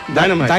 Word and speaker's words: Dynamite: Por algo le dicen Tennessee Dynamita. ¿Eh? – Dynamita. Así le dicen, Tennessee Dynamita, ¿no? – Sí. – Dynamite. – ¿Cómo se dice? Dynamite. – Dynamite. – Yes. Dynamite.